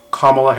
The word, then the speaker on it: Kamala: I can say her name correctly now. Kamala Harris. I kept calling her Kamala Harris, which I realized that that's the way that President Trump says it. And somebody else pointed out that he says her name wrong.